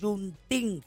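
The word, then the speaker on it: Juneteenth: Ok, Juntín, así se pronuncia, Juntín.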